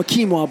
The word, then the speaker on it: quinoa: So we try to hit all the different buttons and take my wife home a quinoa bowl is what I want to do.